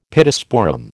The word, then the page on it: Pittosporum: American English